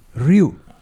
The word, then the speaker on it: Ryu: How's it pronounced correctly? – Ryu.